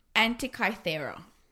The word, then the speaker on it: Antikythera: Okay, so this is a dial that Archimedes created, and it is called the, I have to say this properly, Antikythera.